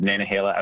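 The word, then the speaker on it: Nantahala: I pronounce it Nanahala. How do you pronounce it? – Yep. So the official pronunciation is Nanahala Outdoor Center. Yeah. Although, as you might imagine, we get all sorts of variations on that pronunciation.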